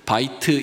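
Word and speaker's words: 바이트 (בית): 여기 집이라고 하는 것은 히브리어로 바이트 이렇게 발음하는데 여기에는 세 가지 뜻이 있어요.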